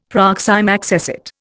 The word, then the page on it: proxime accessit: Indian